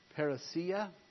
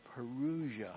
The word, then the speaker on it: parousia: Now it's pronounced parousia or parousia, however you want to pronounce it. I don't care. It doesn't seem to matter.